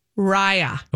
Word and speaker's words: Raya: How do you pronounce it? And what does it mean? – I don't know. – Okay. Raya, I'm working on Raya Sunshine or Raya? – Raya. It is pronounced Raya.